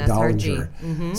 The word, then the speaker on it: Dollinger: And I have somebody with a soft S and a hard G, Leslie Dollinger.